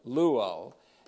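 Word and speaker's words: luo (λύω): The word is luo here. Familiar word if you look at last time's message. It's the present passive participle of the verb luo, L-U-O, long O.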